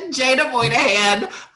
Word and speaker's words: Jaena Moynihan: Yeah, you butchered that one really good. Jaena Moynihan. Hi.